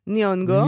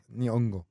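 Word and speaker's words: Nyong'o: אה, השחקנית המקסימה, לופיטה ניוגו, יונג, יונגו. – ניונגו. ניונגו, אבל לא מבטאים את הנון בהתחלה בשמות אה, אפריקאים לדעתי.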